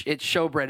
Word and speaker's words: showbread: It looks like shoe bread. It's showbread is how it's pronounced.